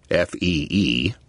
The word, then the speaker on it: FEE: The Foundation for Economic Education, FEE, usually pronounced like the word fee, had been founded just after the end of World War II by a radicalized former Chamber of Commerce executive named Leonard Reed.